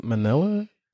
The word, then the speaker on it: Mnelia: I want to say it's Manila. So it's spelled M-N-E-L-I-A.